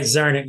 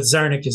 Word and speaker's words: Czarnik: Always. Right, Czarnik. Yeah, some people say Czarnik, but Czarnik is the way I use it, right? – Welcome, as usual, to Politics and Right, Tom.